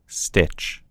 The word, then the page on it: stitch: American English